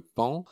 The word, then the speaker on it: paon: Il y a mille choses à raconter sur le pan, euh, qui s'écrit P-A-O-N, hein, bien sûr.